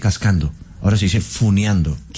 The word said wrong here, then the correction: funando: Que usted, pero eso yo tuve un mensaje de 48 años, por favor. – Sí, funando, me aclaran.